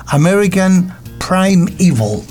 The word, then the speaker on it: American Primeval: Bueno, esta serie de la que tú vas a hablar se pronuncia American Primeval.